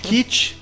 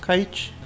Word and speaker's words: Kite: Kit, Kite. Kit. Kit, Pit. Não sei como é que se pronuncia. – Kite? – Kite.